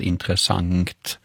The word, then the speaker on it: intressant: Det här uttalet då som Nanna nämner, intressant, det finns också belagt i man kan slå upp i SOB och se att det här har kunnat uttalas intressant med ett ng på slutet. Då.